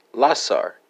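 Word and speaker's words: läser: That's going to be Lego, spelled L-E-G-G-O. And for Norwegian, Swedish, and Danish friends, you have Lesser, Lassar, and Leiser, respectively, spelled L-E-S-E-R, L-A-S-A-R, and L-A-E-S-E-R.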